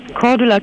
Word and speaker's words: Cordula: Cordula Kine.